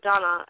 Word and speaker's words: Danu: which translated from Gaelic to English is the tribe of Danu, or Donna as it's pronounced in Gaelic.